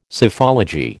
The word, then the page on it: Psephology: American English